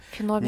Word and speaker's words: феномен: Сегодняшний выпуск посвящен очень необычной книге, которая является такой темной лошадкой, мне кажется, всей литературы, потому что ее феномен или феномен, я вот не знаю, как правильно ставится ударение на это слово, феномен.